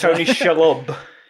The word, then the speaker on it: Tony Shalhoub: Who's Tony Shalhoub. – Tony Shalhoub.